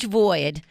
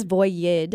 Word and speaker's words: void: And when I went to New York City a couple of months ago, I saw a huge void. Void. – No, I always did I say it correctly? I always say it wrong. – Sometimes she says voyid. – Voyid.